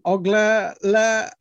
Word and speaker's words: Oglala: You are a member of the Sioux Oglala Nation.